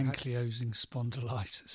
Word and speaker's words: ankylosing spondylitis: Yeah, ankylosing spondylitis.